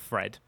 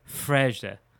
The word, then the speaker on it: Fred: Ah, speaking of Fred, how many times do you think this season commentators are going to use the line, I mean, we pronounce it Fred, but it's actually not pronounced that way. It's pronounced like this in Portuguese.